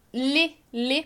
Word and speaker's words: les: Então, lê para marcar o plural. E vai ser com essa pronúncia aberta, né? Lê. Ao contrário do masculino que é le, vai ser lê.